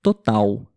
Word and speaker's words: total: e o último exemplo é a palavra total. Total. Tem a letra L no final, mas nós pronunciamos como se tivesse U também, ok?